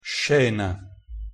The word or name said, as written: scena